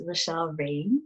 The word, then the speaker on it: Roushelle Reign: It is Roushelle Reign or mm. Roushelle Reign, R O U S H E L L E R E I G N.